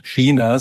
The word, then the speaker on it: China: Ja, also ich bin jemand, der nach wie vor China sagt. Das ist auch die richtige Aussprache.